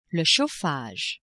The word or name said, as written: chauffage